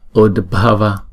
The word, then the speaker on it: udbhava: Udbhava udbhava. Chữ U và chữ D đọc là ud ud.